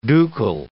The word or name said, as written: ducal